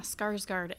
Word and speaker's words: Skarsgård: Yeah, Skarsgård.